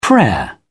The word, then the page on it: prayer: British English